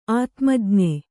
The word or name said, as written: ಆತ್ಮಜ್ಞ